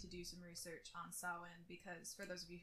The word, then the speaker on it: Samhain: Originally, I was going to be telling y'all about Samhain. Which I believe I said that correctly. Okay. – I'm so proud of you for saying it correctly.